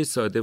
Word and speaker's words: ساده: واژه ساده واژه فارسیه، تشدید اصلا نداره